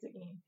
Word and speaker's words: Seguin: I think it's Seguin. Pronounce exactly how it does not look.